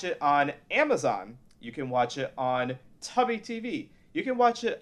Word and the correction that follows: Tubi: Did you say Tubby TV? – It's pronounced Tubi, isn't it? – It's pronounced Tubi. – But it's spelled like tuba, so it must be Tubi, right? – You didn't say Tubi, you said Tubby, like Tubby Custard, like you're a fucking...